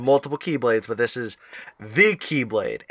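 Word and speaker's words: the Keyblade (χ-blade): So it's shown in game as the X Blade, but it is pronounced the Keyblade. Yes. Because it is like the symbol for key.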